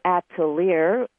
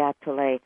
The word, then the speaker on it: Atelier: The healing, I pronounced it originally the Atelier, and it's that's perfect because you spell it that way. – L-I-E-R, but it is French, the Atelier.